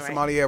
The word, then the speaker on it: sommelier: And this movie did a great job, I thought, of being able to break that down of what a sommelier. I hope I'm saying that right. Am I saying that right? – They were saying sommelier, right? – Yeah.